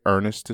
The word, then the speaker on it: Earnest: It's very simple because it's my name, and I was just about to say Earnest is spelled E-A-R-N-E-S-T. It's the British spelling of Earnest, meaning honest and sincere.